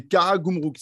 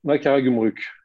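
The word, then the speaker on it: Karagümrük: Euh, c'est Karagumruk, c'est ça C'est ça, ouais. C'est ça. Ah, je l'ai bien prononcé Karagumruk.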